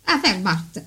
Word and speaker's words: Wehrmacht: come si dice in tedesco, Wehrmacht. – Wehrmacht, l'ho detto così.